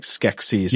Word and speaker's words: Skeksis: Now, you're not pronouncing sexy in a funny way. You're saying like Skeksis, yes. – Like in the Dark Crystal.